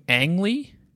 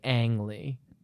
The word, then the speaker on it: Ang Lee: It occurs to me, do you pronounce his name Ang Lee? – Probably. You're the one who's been saying Ong. – I don't know where I came up with Ong. – It's almost certainly Ang Lee.